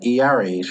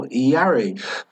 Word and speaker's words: Iare: Like with names, like I was trying to explain my last name to someone yesterday, and I was like, Iare. She was like, Ayar? I was like, no, Iare. Iare. It's three syllables.